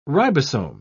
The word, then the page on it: ribosome: British English